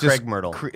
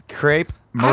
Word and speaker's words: crepe myrtle: But it's spelled weird. I thought it was Craig Myrtle. – It's crepe myrtle.